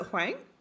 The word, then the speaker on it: Huang: Is it Huang? – It's Huang. – Huang. Okay, cool. Yeah, we've been saying it wrong for a very long time. Apologies. – It doesn't pronounce the way that it looks.